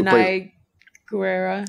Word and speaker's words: Danai Gurira: Did, who, who Danai Gurira.